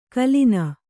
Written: ಗುಲ್ಲನೆ

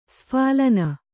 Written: ಸ್ಫಾಲನ

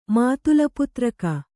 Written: ಮಾತುಲಪುತ್ರಕ

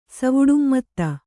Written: ಸವುಡುಮ್ಮತ್ತ

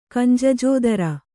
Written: ಕಂಜಜೋದರ

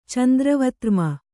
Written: ಚಂದ್ರವತ್ರ್ಮ

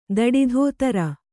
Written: ಧಡದೋತರ

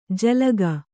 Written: ಝಿಲ್ಲಿಕೆ

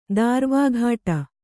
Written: ದಾರ್ವಾಘಾಟ

